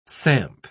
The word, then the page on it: samp: British English